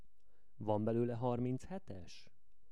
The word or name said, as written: van belole harmichetes